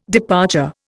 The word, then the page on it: departure: Indian